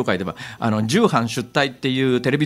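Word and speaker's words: 重版出来: あれ重版出退って読むんですよ。でき重版できって書いてねあのよくできましたのでき書くんですけど、はい、重版重ねる版ができって書いてあれ重版出退と読むんですが、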